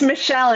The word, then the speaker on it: Michell: It's Michelle, and I can't believe you got it right. So many people get it wrong, and they say Mitchell, but there's no T, but thank you.